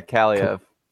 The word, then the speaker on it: Kaliev: Yeah, Kaliev.